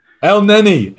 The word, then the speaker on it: Elneny: Elneny.